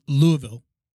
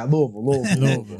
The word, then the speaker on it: Louisville: So well, let me say it right. Louisville. – Yeah, Louisville.